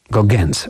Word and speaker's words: Gauguin's: Gauguin's właściwie. Tak się to wymawia, nie jak Paul Gauguin, wirtuos pędzla XIX wieku, ale Gauguin's.